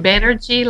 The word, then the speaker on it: Banerjee: Banerjee like energy.